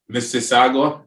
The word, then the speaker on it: Mississauga: Mississauga.